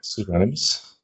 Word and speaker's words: pseudonymous: How do you say that word? – Pseudonymous.